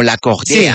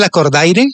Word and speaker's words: Lacordaire: Pero como en francés, como Lacordaire. Sí. Una cosa así. – Es que es un nombre francés y se escribe Lacordaire, pero se pronuncia Lacordaire.